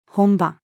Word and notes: 本葉: female

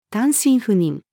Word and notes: 単身赴任: female

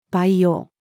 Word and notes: 培養: female

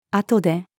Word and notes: 後で: female

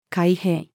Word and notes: 開閉: female

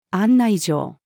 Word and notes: 案内状: female

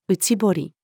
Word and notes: 内堀: female

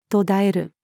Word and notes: 途絶える: female

